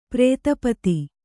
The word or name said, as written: ಪ್ರೇತಪತಿ